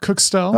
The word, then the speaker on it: Kukshtel: Kyle, how do I say your last name? – Close enough. Kuchstil.